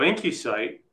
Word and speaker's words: IncuCyte: So IncuSite, and that's spelled I-N-C-U-C-Y-T-E, it's a live cell imaging and analysis machine, and it provides real-time quantification of drug therapy.